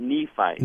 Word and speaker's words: Nephite: But the idea is that there was this Nephite, is that how you pronounce it? – Pronounce it Nephite.